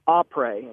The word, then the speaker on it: après: It's pronounced après, and it's French for after ski.